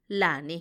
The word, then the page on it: lani: Macuiltianguis